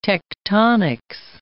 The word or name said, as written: tectonics